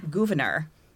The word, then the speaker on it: Gouverneur: In 1864, she traveled to see her brother, Gouverneur Warren. – Gouverneur.